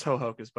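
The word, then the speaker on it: Ho-Ho-Kus: There's dashes, so I imagine there's a small pause in between each ho and cuss. – No, it's just Hohokus, buddy.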